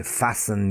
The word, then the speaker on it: fasten: As in many letters, there's also a silent T. So, let me give them to you. Fasten. To fasten your coat. It's a T in the middle, F-A-S-T-E-N, but the T is silent. Fasten. Listen. I like to listen to music. Listen. L-I-S-T-E-N.